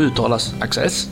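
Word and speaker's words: ACC_XESS: Varför de envisas med att stava på sådär väldigt underliga sätt, det kommer ju ändå att uttalas Access.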